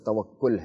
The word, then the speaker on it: توکل: دیکھیں صحیح تلفظ توکل ہے.